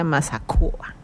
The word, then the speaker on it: mazacua: Entonces vamos a aprender a describir en Yocotán la boa o la mazacua, que se pronuncia mazacua.